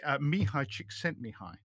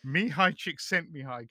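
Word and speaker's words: Mihaly Csikszentmihalyi: I'm brandishing a book here, Finding Flow by Mihai Csikszentmihalyi.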